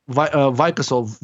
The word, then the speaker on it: Vikasol: Vicasol. V-I-K-A-S-O-L.